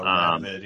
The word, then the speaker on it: Radovid: Oh, Radovid, yeah.